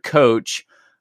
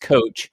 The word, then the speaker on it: Kocs: So the word coach originally referred to a horse-drawn carriage from Coach Hungary, K-O-C-S, but it is pronounced coach.